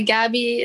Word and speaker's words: GXBY: Um, I go by Gabby.